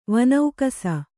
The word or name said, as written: ವನೌಕಸ